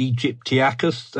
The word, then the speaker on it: aegyptiacus: I want you to take it away, and correct me if I say this wrong. Spinosaurus aegypticus. – Well, you're the classicist, Aegyptiacus.